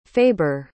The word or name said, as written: faber